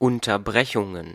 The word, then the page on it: Unterbrechungen: [ˌʊntɐˈbʁɛçʊŋn̩]; /ˌʊntɐˈbʁɛçʊŋən/